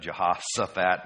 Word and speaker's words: Jehoshaphat: I'm going to just pronounce it the way I learned it as a kid, and that's as a Jehoshaphat instead of Shaphat. In Hebrew, the difference between S and Sh is one dot either here or here.